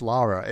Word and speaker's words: Lara: Like, it is Lara. It's never been Laura.